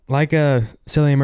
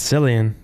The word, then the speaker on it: Cillian: I think it's Cillian. That's how it's spelled.